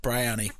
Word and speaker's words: Bryony: Bryony.